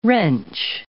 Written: wrench

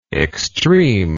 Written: extremeness

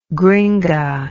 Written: gringa

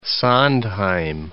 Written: Sondheim

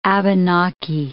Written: Abenaki

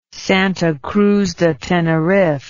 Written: Santa Cruz de Tenerife

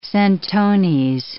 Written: centones

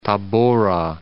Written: Tabora

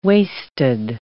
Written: waisted

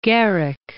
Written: Garrick

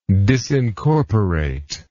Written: disincorporate

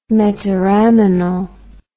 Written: metaraminol

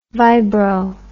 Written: vibro

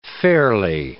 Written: ferly

